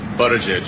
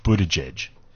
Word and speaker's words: Buttigieg: How do you pronounce this? – Buttigieg. – Yes! You did it! High five! – So one person got it right out of all the people they asked. Buttigieg.